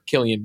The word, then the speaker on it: Cillian: I messed up this week with actor Cillian Murphy. I called him Cillian because of the way it's spelled, the Irish spelling.